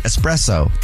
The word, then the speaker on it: espresso: There's no X. – Right, but that's a lot of people say espresso. – Where'd you get the X from? – That's what I wonder.